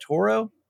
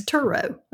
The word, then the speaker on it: Turo: How do you say that? Turo. – Mm-hmm. Like I don't know Toro, I can't say that right. Lawnmowers. – Turo. Yeah. – Yeah. – Okay. – It's Turo. Uh-huh.